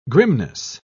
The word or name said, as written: grimness